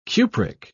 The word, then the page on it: cupric: British English